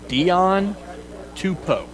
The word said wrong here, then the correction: Sione Tupou: Was I close? – Sione Tupou. You got Tupou.